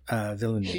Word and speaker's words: Villeneuve: Villeneuve.